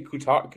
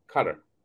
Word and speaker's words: Qatar: Yeah, how how they pronounce it is gutter, cutter. Are you saying gutter or cutter? Cutter. Cutter. Yeah, it sounded like you said gutter at first, but yeah, it's it's similar to gutter, but with a Q.